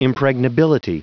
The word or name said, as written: impregnability